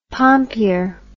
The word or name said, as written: pompier